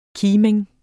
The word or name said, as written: KIMINGEN